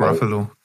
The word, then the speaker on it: Ruffalo: Mark Ruffalo.